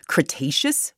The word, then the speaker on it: Cretaceous: All right, for our next story, our third story, we're heading back tens of millions of years ago to the, okay, let me see if I say this right, Cretaceous period? – Nailed it.